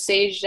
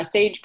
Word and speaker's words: Saje: Yeah, my Instagram handle is Sage Dyer, Sage period Dyer, and my name is spelled S-A-J-E, which is confusing. Most people think it's a G.